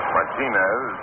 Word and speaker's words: Martinez: Many people call themselves Martinez. Martinez is his pronunciation.